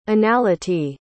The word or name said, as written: anality